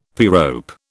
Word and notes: pyrope: British English

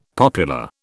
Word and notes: popular: British English

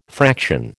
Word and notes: fraction: American English

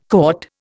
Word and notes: cotta: Indian

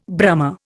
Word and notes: Brahma: Indian

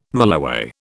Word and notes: mulloway: British English

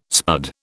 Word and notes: spud: British English

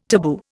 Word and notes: taboo: Indian

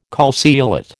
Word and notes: calceolate: American English

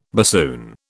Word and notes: bassoon: British English

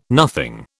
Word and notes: nothing: British English

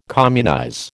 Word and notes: communize: American English

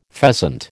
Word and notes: pheasant: American English

